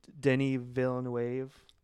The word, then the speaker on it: Denis Villeneuve: Denis Villeneuve, is that how you pronounce his name? – Yeah, starring Timothy Chalamet.